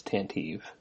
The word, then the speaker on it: Tantive: Well, I've heard multiple pronunciations of it, but I think the current in canon pronunciation most common is Tantive.